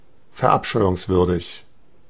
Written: heinous

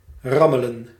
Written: starve

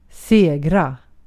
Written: kazanmak

